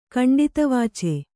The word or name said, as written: ಕಂಡಿತವಾಚೆ